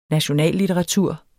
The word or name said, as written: nationallitteratur